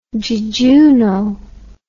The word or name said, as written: jejuno